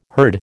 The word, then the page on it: herd: American English